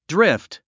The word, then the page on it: drift: dríft; ドリフト